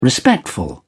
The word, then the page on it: respectful: British English